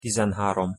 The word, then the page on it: tizenharom: Hungarian